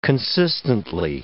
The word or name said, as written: consistently